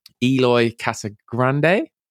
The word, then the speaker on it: Eloy Casagrande: I was on Eloy Casagrande.